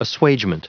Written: assuagement

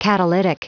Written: catalytic

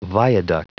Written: viaduct

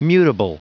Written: mutable

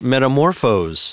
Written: metamorphose

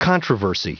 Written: controversy

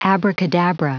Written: abracadabra

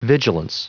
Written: vigilance

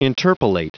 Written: interpolate

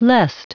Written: lest

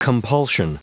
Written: compulsion

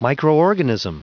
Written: microorganism

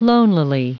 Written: lonelily

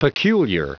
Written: peculiar

